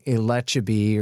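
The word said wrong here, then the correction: Elchibey: or something like that. – oh, Elchabi. Yeah, yeah, that guy.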